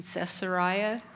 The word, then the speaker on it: Caesarea: The following day, he arrived in Caesarea. How do you say it? – Yep, that's good.